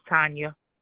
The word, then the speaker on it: Tanya: My name is Tanya Artis.